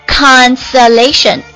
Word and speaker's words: consolation: 接下来我们看一下它的名词，动词变为名词，读音呢嘿，奇怪了，它跟它的名词还比较相似，都是前面发这个大嘴的啊。 Consolation C-O-N-S-O-L-A-T-I-O-N 名词，表示使人感到安慰的人或者事情，安慰慰藉，比如说几句安慰的话， A few words of consolation。